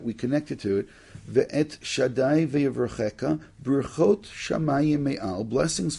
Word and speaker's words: Shaddai: Me'el avicha v'ezreka ve'et shad, I'm going to say the word Shaddai, or we pronounce it Shakai.